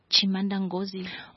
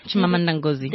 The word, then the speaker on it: Chimamanda Ngozi: Is her name Chimamanda Ngozi? – Oh, Chimamanda. Chimamanda Ngozi. – There we go. Yes.